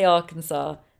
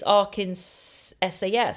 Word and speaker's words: Arkansas: Because the way I pronounce it is as though it's A-R-K-A-N-S-A-W, Arkansas. But it's Arkansas, so it's Arkansas.